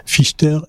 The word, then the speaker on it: Fichter: Désolé Bertrand si j'ai écorché ton nom. Fichter, F-I-C-H-T-E-R.